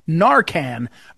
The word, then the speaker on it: Narcan: Excuse me, I'm mispronouncing it. Narcan. Narcan is an incredible drug that was designed to counteract fentanyl overdose.